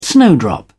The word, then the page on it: snowdrop: English; British English